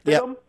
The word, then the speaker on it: Stidham: Is it Stidham? – Yep.